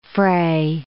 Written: frae

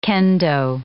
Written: kendo

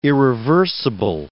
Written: irreversible

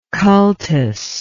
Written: cultus

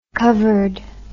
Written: covered

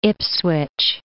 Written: Ipswich

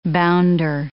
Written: bounder

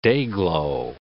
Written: dayglow

